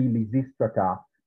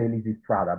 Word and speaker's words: Lysistrata: Well, this is a long controversy because, you know, you pronounce Greek names according to the Latin pronunciation. And according to the rules of Latin pronunciation, the penultimate syllable is what determines accentuation. So since that A is short, the accent is going to be recessive. So it should be Lysistrata, but people say Lysistrata.